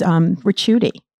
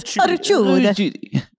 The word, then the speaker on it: Ricciuti: It's Ricciuti. – Ricciuti. – You got to say it like this with the Italian hands. Ricciuti.